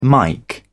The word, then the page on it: mike: British English